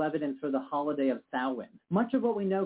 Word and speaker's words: Samhain: The Celtic festival Samhain is believed to be the predecessor of our contemporary holiday Halloween. And yes, it's pronounced Samhain despite that spelling.